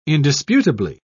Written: indisputably

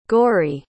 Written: gorey

